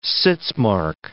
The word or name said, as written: sitzmark